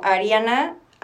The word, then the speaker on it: Ariana: Me pueden encontrar en Instagram como Ariana, A-R-I-A-N-A. Em, ¿Cómo se dice? Underscore. – Guión bajo. – Eh, guión bajo. H-A-L-S-E-Y.